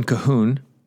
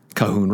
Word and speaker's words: Colquhoun: John Colquhoun, pronounced K-A-H-O-O-N, but looks it's spelled C-O-L-Q-U-H-O-U-N,